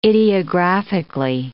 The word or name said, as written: ideographically